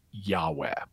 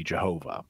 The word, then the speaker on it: Yahweh: which is commonly today pronounced Yahweh. Although you'll also sometimes hear kind of the older way to say it would be Jehovah.